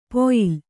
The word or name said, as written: ಪೊಯಿಲ್